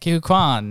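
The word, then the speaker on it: Ke Huy Quan: Uh, is it Ki Kihui Kwan? Is that yes, how you say it? Kihui Kwan.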